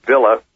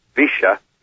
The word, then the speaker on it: Villa: In a town also in the Cordoba province, Villa, or as they pronounce it in Argentine Spanish, Villa, Vicia General Belgrano.